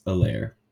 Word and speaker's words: Helaire: Helaire, sorry, the H is silent. Clyde Edwards Helaire.